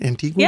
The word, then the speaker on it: Antigua: Is that, am I saying that right? Antigua? – Yeah.